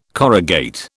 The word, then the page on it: corrugate: British English